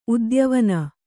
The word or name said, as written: ಉದ್ಯವನ